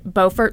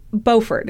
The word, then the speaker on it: Beaufort: And I said, I assume it's pronounced like Beaufort, South Carolina. My mistake. There's a Beaufort, North Carolina that's pronounced Beaufort, but apparently in South Carolina, they call it Beaufort.